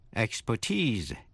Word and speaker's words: expertise: Và danh từ expertise, E-X-P-E-R-T-I-S-E, expertise, là nhấn vần ba, có nghĩa là tài năng chuyên môn.